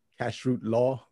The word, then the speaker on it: kashrut: And how do you say kosher? – Kashrut law. We call it kashrut law or kosher.